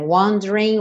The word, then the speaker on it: wondering: And I'm wondering, aqui também, and I'm wondering what you're dreaming, what you're dreaming. Wondering if it's me you're seeing. Só lembrando, nessa palavra wondering, o E do meio não é pronunciado. Então, não diga wondering. Wondering.